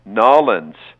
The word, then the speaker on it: Nawlins: I did learn how to spell New Orleans, It is spelled N-A-W-L-I-N-S. Nolens is how they say it there.